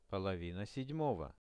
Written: polovina 7-ogo

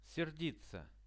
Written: serditsia

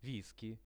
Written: wiskey

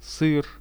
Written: syr

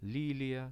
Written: lilia

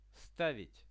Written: stavit

